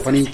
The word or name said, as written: panit